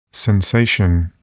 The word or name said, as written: sensation